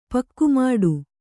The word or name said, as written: ಪಕ್ಕುಮಾಡು